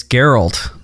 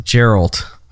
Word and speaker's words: Geralt: Well, before we get another correction on this, it's Geralt, not Geralt.